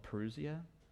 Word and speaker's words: parousia: we have what is called the parousia. That's not how a Greek person would pronounce it, but that's how we'll pronounce it,